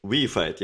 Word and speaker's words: reified: Is it reified or reefed or what? How do you say that word? – Reified? – I think it's called reefied, yeah. – Oh, reefied, okay. Reefied. Yeah, I've been pronouncing it wrong.